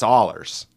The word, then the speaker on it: Ehlers: Ehlers Ollers. It's Ollers. That's how I've heard everybody say it,